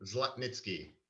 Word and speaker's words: Zlotnitsky: Go ahead and say how it really should be pronounced. – Zla- Zlotnitsky.